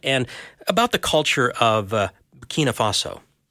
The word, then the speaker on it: Burkina Faso: Burkina Faso, yes. – Burkina. I always pronounce it wrong. Burkina Faso. So, well, anyway, thanks for joining us this morning on Mornings.